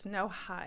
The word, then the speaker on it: Noha: Yes, it's Noha, and it's spelled N-O-H-A. So it's said the way it's spelled.